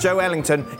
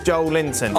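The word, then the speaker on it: Joelinton: Joe Ellington is our Brazilian researcher, and he wrote, Joe Linton is pronounced Joe Ellington. It's not pronounced Joe Linton.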